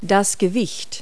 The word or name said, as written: weight